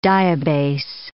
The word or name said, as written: diabase